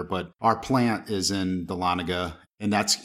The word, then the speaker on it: Dahlonega: Most people are like Dahlonega, and it's no, it's Dahlonega.